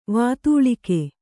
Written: ವಾತೂಲಿಕೆ

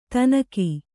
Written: ಥಣ್ಣಗೆ

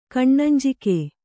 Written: ಕಣ್ಣಂಜಿಕೆ

